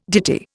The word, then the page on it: ditty: Indian